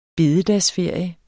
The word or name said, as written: bededagsferie